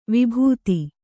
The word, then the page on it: ವಿಭೂತಿ: Kannada